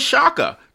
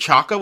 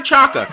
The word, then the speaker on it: Chaka: Maya Shaka. This is awesome. She has a great name. Actually, I think she pronounces it Chaka, which is like a mispronunciation, but no judgment. Maia Chaka, you go, girl.